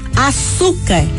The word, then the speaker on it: açúcar: Açúcar é uma palavra paroxítona porque tem a penúltima sílaba tônica. A sílaba su é pronunciada mais forte e açúcar termina em R.